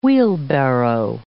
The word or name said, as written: wheelbarrow